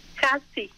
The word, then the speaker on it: Hati: ¿Axti? – Hati. – Hati. – Hati. – Hati. Ok. – Sí, así, sí, más o menos.